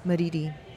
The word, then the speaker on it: Madidi: Madidi. – How would you spell that? – M-A-D-I-D-I.